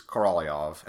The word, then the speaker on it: Karamazov: And I'm pretty sure that the correct pronunciation is Karamazov, and I make an effort to pronounce things, names and words and foreign languages correctly.